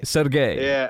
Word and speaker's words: Sergei: Did we conclude in the end that it was Sergei? – Sergei. – Yeah. – But we had to go. We had to return to the old pronunciation.